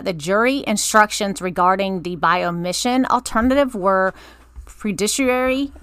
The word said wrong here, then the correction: prejudicially: Am I saying that right? – Prejudicially inefficient, erroneous, and misleading because the circuit court failed to accurately define the term crime.